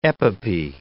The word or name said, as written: epopee